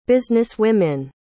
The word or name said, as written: businesswomen